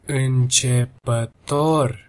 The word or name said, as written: incepator